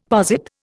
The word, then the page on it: posit: Indian